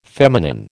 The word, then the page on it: feminine: American English